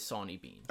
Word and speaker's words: Sawney Bean: As far as I know, all of the American pronunciations I've ever heard of it is Sawney Bean.